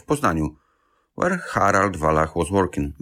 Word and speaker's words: Harald Wallach: But the word Wallach is a German word. That's why I wasn't sure. But he's pronounced Harald Wallach.